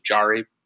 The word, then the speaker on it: Jarry: Jarry, sorry. I called him Jarry all year last season. So it stuck in my head. Yeah, I thought it was a silent J.